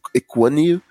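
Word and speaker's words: Ekwonu: Eu vou dizer que eu gosto um pouco mais do Ikin Ekwunil, é, a pronúncia do nome dele é Ekwunil, é, segundo ele mesmo falou,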